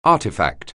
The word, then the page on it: artifact: anglais